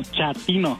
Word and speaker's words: chatino: Chapino. – ¿Chapino? – No, chatino. – Es como chato, ¿verdad? Chatino. – Sí, azunero como chato, chatino.